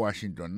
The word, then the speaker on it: Washington: Si nous mettons accent sur accent encore, on va voir que mot Washington, l'accent tombé sur le premier syllabe là.